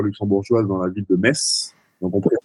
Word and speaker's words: Metz: Donc je suis à Metz, pas Metz. Comme certains peuvent prononcer, c'est bien Metz.